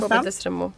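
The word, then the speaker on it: Kobe Desramaults: Kobe Destremaux. Hur man uttalar Kobe Destremaux.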